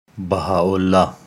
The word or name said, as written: Baha'u'llah